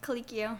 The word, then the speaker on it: Colicchio: Tom Colicchio. – Colicchio.